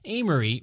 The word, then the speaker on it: Aimery: Amory, which is spelled A-I-M-E-R-Y, invited his younger brother to come to Jerusalem, who accepted the invitation and arrived somewhere around 1180.